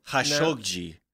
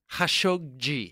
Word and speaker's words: Khashoggi: And also, new pronunciation alert. We are calling Jamal Khashoggi. – Khashoggi. – Yeah. – Yeah. Not Khashoggi. – Yeah, we were Khashoggi. – I was saying it like it was a fucking sandwich from Chicago.